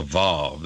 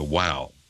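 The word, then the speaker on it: waw: Some traditions call it the vav, they harden it. Well, that's a rabbinical tradition to harden that particular letter, the wow. W-O-W, that's the way it's pronounced. It's not pronounced with a V.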